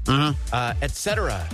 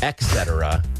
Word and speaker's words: et cetera: Et cetera. It is not pronounced et cetera. – Okay. – It's et cetera. – There's not a K.